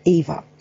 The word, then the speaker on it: YVA: Harley is one of the directors and founding members of an exciting new Australian group called Young Voices for Animals, or YVA, which is pronounced EVA.